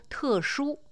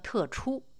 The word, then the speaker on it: 特殊: 习近平有些字的发音是出自其出生之地的北京方言，例如“ 特殊”，他念成了“ 特出”，出兵的“ 出”；“ 乒乓”，他说成了“ 乒乓”，导致这个毛泽东时代“ 乒乓外交” 的典故有变成“ 乒乓外交” 之嫌。